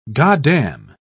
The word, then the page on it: goddam: British English